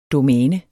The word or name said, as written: domæne